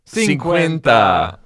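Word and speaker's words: cincuenta: Cinquecenta? Cinquecenta. I was right. – Cinquenta. Cinquenta. – I had a lot of the right letters.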